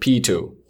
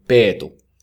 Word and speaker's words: Peetu: For most people who speak English or whatever language, they normally pronounce it Pitu. So, but in Finnish, which is my native language, we say Peetu.